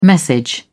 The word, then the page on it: message: British English